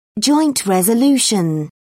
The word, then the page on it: joint resolution: British English